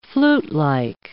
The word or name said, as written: flutelike